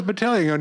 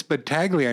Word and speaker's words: Battaglia: Number two, my name has a silent G in it. – Battaglia.